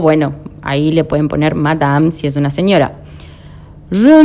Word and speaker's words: Madame: Madame, la E final, obviamente ya aprendimos, no tiene tilde, no se pronuncia.